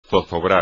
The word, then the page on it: zozobrar: espagnol